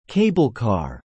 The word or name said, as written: cablecar